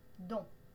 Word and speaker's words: dont: On ne prononce pas le T dans don. Don.